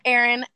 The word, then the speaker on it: Aaryn: Yeah, so it's pronounced Aaryn.